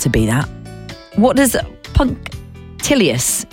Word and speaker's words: punctilious: Is punctilious. Punctilious. P-U-N-C-T-I-L-I-O-U-S. Punctilious. I wanted to pronounce it as punctilicious. Punctilicious. But it's definitely not.